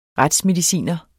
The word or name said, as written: retsmediciner